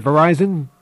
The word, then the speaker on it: Verizon: The word Verizon, pronounced the way I'm saying it, according to them, was selected from more than 8,500 names.